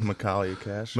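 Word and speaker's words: Michaelia Cash: You know, you see Makalia Cash, I think I said her name right. – Makalia Cash. – Makalia Cash.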